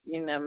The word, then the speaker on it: Gina: Gina Mineo. – Okay. Could you spell that first name for me, please? – G-I-N-A. M-I-N-N-E-O.